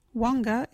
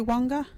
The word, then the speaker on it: wangga: local Wajiri word is wanga. What is wanga? Wanga is talk. You can use wanga in this sentence. My little friends are learning Wajiri wanga. Wanga is spelt W-A-N-G-G-A. Can you say wanga?